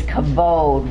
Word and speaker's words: kavod: From God's perspective, the Hebrew word for glory, God's glory, is kavod, which means weightiness. I love that. K-A-V-O-D is the pronunciation, phonetic spelling of kavod, and it means weightiness.